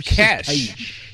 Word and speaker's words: cache: So, yeah, it's cash. It's cash.